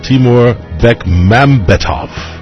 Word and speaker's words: Timur Bekmambetov: Timur Bekmambetov.